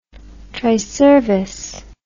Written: triservice

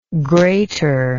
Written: grater

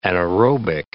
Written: anaerobic

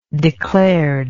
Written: declared

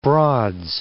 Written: Broads

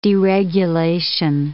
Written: deregulation

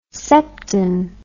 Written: septan

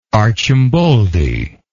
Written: arcimboldi

